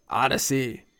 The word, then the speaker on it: Audacy: That's spelled A-U-D-A-C-Y, Odyssey.